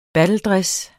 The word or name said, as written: battledress